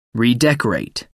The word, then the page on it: redecorate: American English